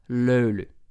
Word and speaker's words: löyly: And they are there for löyly. Löyly, okay? Löyly is spelled L-Ö-U-L-Ö.